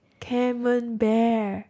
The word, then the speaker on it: Camembert: Camembert. – Oh no, I've been saying it wrong my entire life. – It's camembert.